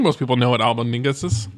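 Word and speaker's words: Albóndigas: Albondigas? – Yeah, man.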